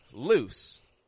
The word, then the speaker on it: loose: Whereas loose, loose, is very quick in the vowel and it goes straight into an S.